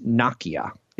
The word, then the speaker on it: Nokia: And then sexy European player Nokia, also known as Nokia, if you were to say it correctly.